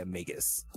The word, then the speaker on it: Magus: Is that how it's pronounced? Magus? – Yeah, Magus. – Oh, I've been saying Magus.